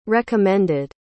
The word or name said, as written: recommended